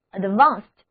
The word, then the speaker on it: advanced: 字母组合 ed 在这里读作清辅音 t， advanced。